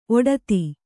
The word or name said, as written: ಔಡೊತ್ತು